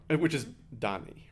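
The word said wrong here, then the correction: Diné: right? Is that how you say the language? – Dene.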